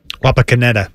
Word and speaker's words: Wapakoneta: Wapakoneta.